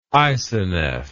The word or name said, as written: isoneph